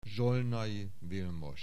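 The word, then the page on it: Zsolnay Vilmos: Hungarian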